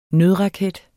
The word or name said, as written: nødraket